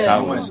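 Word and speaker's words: Sauron: 실제로 이제 소론이라는 그 소론 이런 식의 그 발음이 대체로 사우론이라고 음. 예, 네, 사우론.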